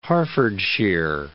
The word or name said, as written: Hertfordshire